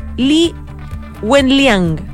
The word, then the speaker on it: Li Wenliang: Hoy día China estuvo pendientísima del caso de Li Wenliang.